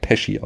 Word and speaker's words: Pesci: Oh, und, bei dem weiß ich auch, er wird Pesci ausgesprochen.